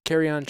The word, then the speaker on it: Kerryon: All right, next injury up is Detroit Lions running back Kerryon Johnson.